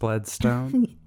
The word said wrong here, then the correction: Bledsoe: Bledsoe. Not Bloodstone.